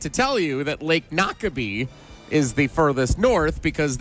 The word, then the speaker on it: Noquebay: Is it Shawano Lake, Lake Wissota, or Lake Noke Bay? – Nakabee.